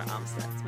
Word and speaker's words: Amstutz: You can find me on every social media platform at Sandra Amstutz.